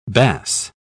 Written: bas

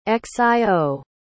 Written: xio

